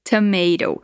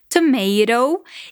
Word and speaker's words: tomato: Um detalhe interessante aqui é a pronúncia de tomato. Nos Estados Unidos, eles geralmente dizem tomato, enquanto no Reino Unido, na, Inglaterra, eles dizem tomato. A escrita é a mesma, o que muda só a pronúncia.